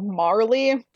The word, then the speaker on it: Marley: We're locking with Jacob Morley. – I need to make a judgment call here because it's actually Jacob Marley, like Bob Marley.